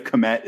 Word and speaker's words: Kmet: Cole Kmet, and I have no idea if Kmet is the correct pronunciation, but that's what we're going with. – It is.